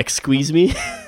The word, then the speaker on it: XQUESME: I'll spell it out for you first, XQUESME, which phonetically comes out to Exqueeze Me, which he found to be very appropriate for a Corvette, and I agree.